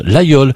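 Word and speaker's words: Laguiole: Ça s'écrit l'Aguiole, mais on prononce l'Aïole.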